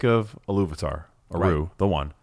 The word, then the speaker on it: Iluvatar: It's Iluvatar. – Yeah. – Iluvatar. That's just how they say it in the audiobook, and I love it.